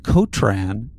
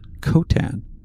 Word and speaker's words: Kotan: It always says Kotran, and everyone in the show pronounces it Kotan.